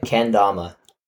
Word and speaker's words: Kendama: Am I pronouncing it right? Like, what? Can you tell me about it? – Yeah, you got it right. Kendama.